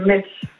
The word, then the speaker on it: Metz: Metz ou Metz? La ville. – C'est Metz.